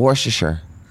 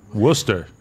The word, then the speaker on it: Worcestershire: Oh. Worcestershire. – No, Worcester. – Worcestershire. – Worcester. – It's Worcestershire.